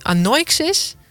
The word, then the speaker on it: Anoixis: En bij mij aan tafel zit Stichting Anoixis. Zeg ik het goed? – Van Alpen, maar Anoixis had je goed.